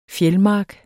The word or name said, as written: fjeldmark